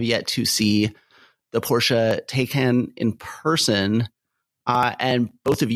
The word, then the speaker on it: Taycan: but it's pronounced Taycan. – Yeah. – For all those in the listening audience.